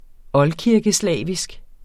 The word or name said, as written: oldkirkeslavisk